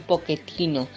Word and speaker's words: Pochettino: Se dice un poquetino. Es un nombre que es italiano y por lo tanto la pronunciación hay que mantenerla como tal en italiano. Otra cosa es que el señor este haya vivido en Argentina, pero eso no tiene nada que ver para que cambiemos la pronunciación de su apellido.